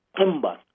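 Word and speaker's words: Themba: To give hope, it's from Nelson Mandela's tribe in Africa, in South Africa, and it's actually pronounced Timba with a click to it in South Africa and other places in the motherland.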